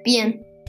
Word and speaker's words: bien: Bien, bien. You say, it fast. It's spelled a little bit like bean, not really. Bien, B I E N.